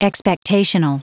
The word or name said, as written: expectational